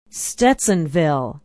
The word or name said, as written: stetsonville